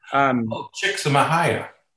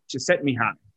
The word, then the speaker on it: Csikszentmihalyi: Csikszentmihalyi. – He pronounces it Csikszentmihalyi.